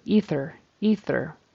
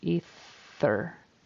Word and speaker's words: ether: It should be E, long E, and a soft TH. But that was good that he got the voice TH because he's been missing those in other words. So ether with a long E and a unvoiced TH, ether.